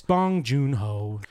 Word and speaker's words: Bong Joon-ho: Is it pronounced Bong Joon-ho or Bong Joon-ho? – It's Bong Joon-ho.